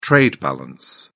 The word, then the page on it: trade balance: British English